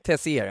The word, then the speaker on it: Tessier: Hur uttalar jag det? – Tessier, ja precis som Tessier. – Gjorde det. – Ja. Tessier, ja. Tessier.